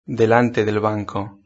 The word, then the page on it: delante del banco: European Spanish